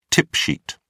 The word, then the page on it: tip sheet: British English